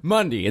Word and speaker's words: Mundy: Mundy. It's Monday, but it's spelled with a U, and it's pronounced Mundy.